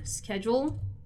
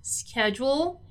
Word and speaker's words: schedule: So this word here, schedule, is pronounced schedule in the North American version. The British version is actually schedule, right?